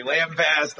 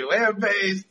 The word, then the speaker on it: lambaste: lambasting, you say lambast, I say lambaste.